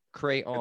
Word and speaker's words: crayon: So the dictionary states the proper way to pronounce crayon is in two syllables. K-R capital A-O-N. Crayon.